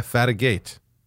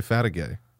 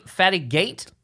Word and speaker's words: Fatigate: And it's funny because you know how to spell my name, but you still pronounce it with a Y at the end. – Do I? – Yeah, Fatigate. But you say Fatigay.